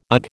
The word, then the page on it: ugh: American English